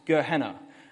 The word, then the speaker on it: Gehenna: I'm not sure about the pronunciation. I rely on other Greek scholars to tell me about that. But Gehinnom, and then it's sort of transliterated. It's the pronounced Gehenna.